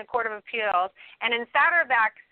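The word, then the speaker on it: Saterbak: And what about the holding in, how you pronounce it, Satterback? – Yeah, it's Satterback.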